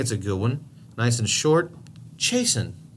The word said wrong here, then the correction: chasten: You want to spell it? – C-H-A-S-E-N? – S-T-E-N. Chasten.